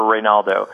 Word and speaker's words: Reynaldo: I've never seen anybody with the name Rey spelled R-E-Y. That's an interesting spelling. Were your parents hippies? – No. It's actually short for Reynaldo. – Oh, cool. – Which is spelled R-E-I-N-A-L-D-O.